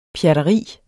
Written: pjatteri

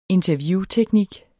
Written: interviewteknik

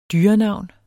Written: dyrenavn

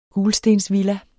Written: gulstensvilla

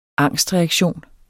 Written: angstreaktion

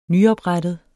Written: nyoprettet

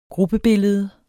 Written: gruppebillede

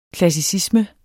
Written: klassicisme

